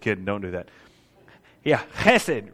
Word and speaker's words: chesed: The Hebrew word, if you were to spell it, is H-E-S-E-D. But it's one of those real Jewish Hebrew words that you have to say like you're clearing your throat, right? And it's chesed.